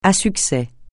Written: à succès

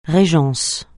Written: régence

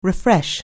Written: refresh

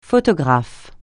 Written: photographe

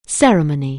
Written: ceremony

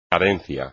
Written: cadencia

